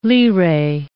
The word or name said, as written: lire